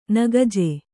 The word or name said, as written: ನಾಕಜ